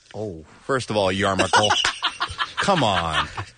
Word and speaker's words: yarmulke: Yarmulke. Is the pronunciation.